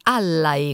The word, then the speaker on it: Allai: Cara Sabrina, si dice Allai con l'accento sulla prima A.